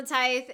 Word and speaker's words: tithe: So now we're coming up on the tithe. And basically. – Rhymes with trife. Because it's trifling.